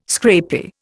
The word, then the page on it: scrapie: Indian